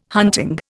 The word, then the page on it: hunting: Indian